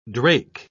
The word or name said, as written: drake